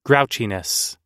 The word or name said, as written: grouchiness